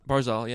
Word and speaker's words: Barzal: Barzal, I don't know how to say his name. – Barzal, yeah.